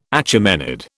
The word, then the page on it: Achaemenid: British English